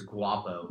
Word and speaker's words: Guapo: It's Guapo. – Guapo. – Not really pronounce the G in it.